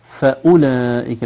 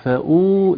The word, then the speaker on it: فأولئك: الواو مرسومة يا شريفة لكن عليها علامة سكون مستدير فوق الواو يدل على أنها لا تنطق. تقولي فأولئك، ليست فأو، ليس فيها مد.